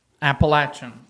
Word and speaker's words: Appalachian: How do you pronounce the mountains that's in Boone? – Appalachian. – That's right. But when you watch the news, and it's they say Appalachian. – Appalachian. – And they're incorrect.